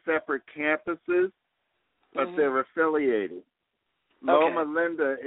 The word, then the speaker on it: Loma Linda: Loma Linda. – Say it one more time. – Loma Linda. – Yeah, Loma L-O-M-A.